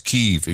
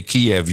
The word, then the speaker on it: Kyiv: Oh no, it's Kyiv. If you say Kiev, you're carrying water for Putin. – I thought it was the Russians that pronounced it Kyiv. – No, the Ukrainians do Kyiv, Kiev in Russian, Kiev. And we say Kiev, or at least we used to, until the whole media changed.